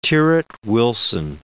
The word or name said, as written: Tyrwhitt-Wilson